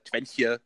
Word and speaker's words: Twente: Da war ein Polder, da war, äh, keine Ahnung, der Twente-Kanal ist nicht, oder wie heißt der Twentje, was weiß ich, wieder ausgesprochen wird, Twente, ja.